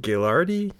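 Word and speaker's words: Guaraldi: Gilardi.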